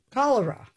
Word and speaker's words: cholera: And it's the diarrhea detector, and it uses AI, and it's going to help people in areas with a lot of chloria. Am I pronouncing? – Cholera. – Cholera.